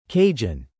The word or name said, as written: Cajun